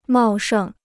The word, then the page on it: 茂盛: mào shèng